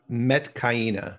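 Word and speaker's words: Metkayina: I think known as the Metakina, if I hope I'm saying that correctly. Or is that Metakina or Mayatkina? – It's Metkaina.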